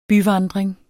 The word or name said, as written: byvandring